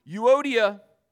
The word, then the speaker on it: Euodia: Euodia and Syntyche. That's how you pronounce these names.